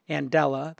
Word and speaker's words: Andela: And I was blown away when I met Jeremy Johnson from Andela. That's pronounced A-N-D-E-L-A, Andela, like Mandela, as in Nelson.